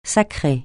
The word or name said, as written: sacrer